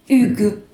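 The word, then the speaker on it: Hugues: Das zweite Buch ist von Pascal äh, Hugu. Ich äh, werfe einen Blick, weil ich nicht weiß, ob ich es richtig ausgesprochen habe. Pascal Hüge. Hüge, Dankeschön.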